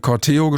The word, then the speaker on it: Corteo: Es heißt so viel wie Umzug und es wird zumindest laut einiger Ausspracheseiten inklusive Hörbeispiel Corteo gesprochen, also Betonung auf dem E.